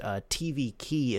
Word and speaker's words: tvikey: It's a keyboard and mouse driver pronounced tvikey, it looks like, but it's spelled T-V-I-K-E-Y.